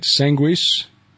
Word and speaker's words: Sanguis: I'm not going to get this right. Sanguis. – That's it. – So that is the Latin pronunciation.